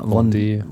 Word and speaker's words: Vendée: ausgesprochen habe, das ist Französisch. Mit V. Von, ja, Vendée. Vendée Globe.